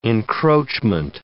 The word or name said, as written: encroachment